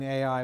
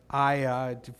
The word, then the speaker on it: Ai: So Ai in Hebrew, I've been saying Ai, but it's actually just pronounced I, or Aya, depending on if you put a vowel there or not.